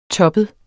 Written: toppet

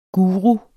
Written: guru

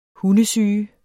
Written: HUNDESYGES

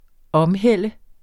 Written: omhælde

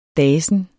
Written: dasen

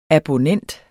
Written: ABONNENTS

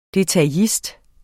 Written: detaillist

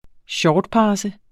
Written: shortpasse

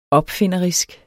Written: opfinderisk

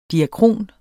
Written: diakron

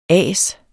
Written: ASERNE

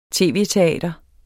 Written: tv-teater

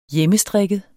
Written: hjemmestrikket

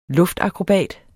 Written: luftakrobat

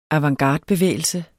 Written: avantgardebevægelse

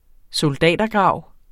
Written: soldatergrav

